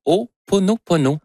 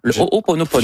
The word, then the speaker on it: Ho'oponopono: Non, c'est une philosophie hawaïenne de, je vais le prononcer comme il faut, de Ho'oponopono. Ho'oponopono.